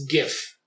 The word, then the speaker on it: GIF: It's a hard G. It's GIF because it says graphical interface format.